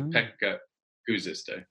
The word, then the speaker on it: Pekka Kuusisto: Pekka Kuusisto, is that about right? – Correct, yeah. In Finnish, you put the emphasis on the first syllable, often, nearly always, of words. So Pekka Kuusisto.